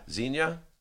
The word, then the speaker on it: Xenia: But if they were hitting hard, Xenia? Is that how we decided we're pronouncing it? – Xenia. – How do you know it's pronounced? – It was Johnny Joe. He used to live near there, and he knows how to pronounce it.